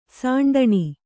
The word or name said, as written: ಸಾಂಡಣಿ